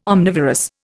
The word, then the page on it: omnivorous: Indian